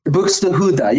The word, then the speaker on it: Buxtehude: But the song Dietrich Buxtehude. – Buxtehude, yeah, you said it right.